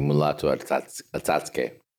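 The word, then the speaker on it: Mulatu Astatke: The standout stuff is by the Ethiopian sort of jazz musician Mulato Astatke. Correct me if I've mangled that. – You pronounced it correctly, Mulato Astatke.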